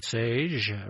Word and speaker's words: Sage: All right, let's look ahead to a track from Sage, rhymes with beige.